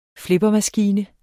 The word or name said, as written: flippermaskine